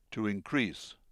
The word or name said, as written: to increase